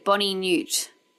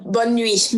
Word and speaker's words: bonne nuit: and the boy pronounced it Bonnie Newt. – Instead of bonne nuit.